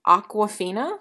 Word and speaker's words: Awkwafina: It is Sandra Bullock, Kate Blanchett, Anne Hathaway, Mindy Kaling, Sarah Paulson, Awkwafina. I don't know who that is. – That's a brand of water. – It's spelled Aqua, like awkward.